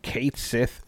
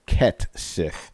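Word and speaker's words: Cait Sith: Kate Sith seems to be pronounced Ket Sith.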